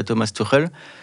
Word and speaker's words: Thomas Tuchel: Euh, 1m80, 75 kg, il est trapu, il a, il a cet esprit guerrier en fait que voulait Thomas Tuchel euh, je ne sais pas si je le prononce bien.